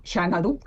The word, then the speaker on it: Xanadu: se pronuncia Xanadu. – Claro, claro, claro, como la película y la canción.